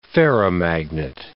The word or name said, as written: ferromagnet